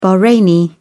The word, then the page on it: bahraini: British English